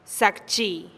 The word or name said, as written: zakchi